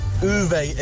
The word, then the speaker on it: Uwe: And Uwe is the correct name to pronounce. U-W-E. It's a Hungarian name. – Oh, Uwe.